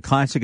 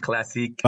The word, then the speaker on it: Classique: So do you pronounce it Classic or Classique? – It's Classique.